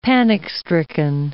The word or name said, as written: panic-stricken